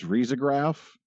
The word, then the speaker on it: risograph: I actually, the more correct way to pronounce it is risograph.